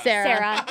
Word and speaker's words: Cera: Where two best friends are on their last weeks of high school, played by Jonah Hill and Michael Cera. Am I saying that right? – Sarah.